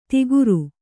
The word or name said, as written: ದಿಗರು